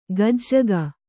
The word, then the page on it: ಗಜ್ಜಗ: kannada